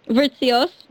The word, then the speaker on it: Vritsios: So welcome, Eve Vritsios. Kind of, did I almost? – Just sorry if you, what? – Vritsios.